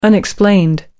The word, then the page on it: unexplained: American English